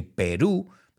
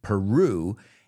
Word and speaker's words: Peru: I know you say Peru, but we say it like a rrr, Peru,